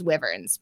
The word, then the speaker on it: Wyverns: But by the way, your um, SK Wyverns, which I know I pronounced wrong on like several podcasts because you told me in the very beginning that it was Wyverns.